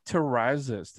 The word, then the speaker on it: Terrazas: And today on the show, I am joined by the lovely Aubrey Terrazas.